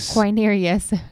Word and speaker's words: Quirinius: Quirinius.